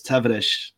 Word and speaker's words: Tavares: They say it, the way they say the S is like with a sh at the end, so it's Tavares.